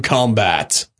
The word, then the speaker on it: combat: No, but you want to put the emphasis on the last syllable if it's to fight. Combat is, we're going, like, it's the noun, whereas he must combat it is the verb, right? – It's the same word, man.